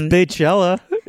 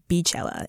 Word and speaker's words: Beychella: Steve, you actually, the pronunciation is not bay, it is bee. – Hence the beehive. – The beehive, queen bee. – But it's Beychella. – As DJ Khaled said, pronounced during the Coachella performance, it's Beychella. It is now known as Beychella.